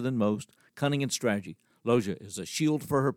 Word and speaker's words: Lozen: But to expand resources, there were some women warriors, the most notable L-O-Z-E-N, silent N, Lozia.